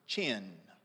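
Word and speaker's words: chen (חֵן): The word favor there in the Old Testament is the word chen. We would spell it C-H-E-N.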